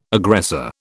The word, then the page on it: aggressor: British English